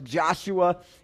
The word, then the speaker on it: Joshua: It's pronounced Joshua in the Hebrew language and Jesus in the Aramaic.